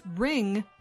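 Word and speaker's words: wring: That is ring beginning with a silent W.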